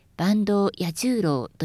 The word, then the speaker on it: 坂東彌十郎: その他、お客様の耳になまって聞こえてしまいそうなのは、尾上松也、中村小太郎、坂東彌十郎です。松也は尾上松也ではなく尾上松也と平らに言います。小太郎は小太郎ではなく中村小太郎と初めにアクセントがきます。彌十郎は彌十郎ではなく真ん中を高くして坂東彌十郎というのだそうです。